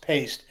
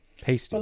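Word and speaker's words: Paiste: Well, I sent you a one that says it's pronounced paste.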